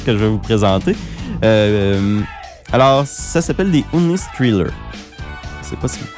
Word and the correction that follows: Honest Trailer: Honest Trailer. – Honest Trailer.